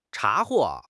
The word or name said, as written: 查获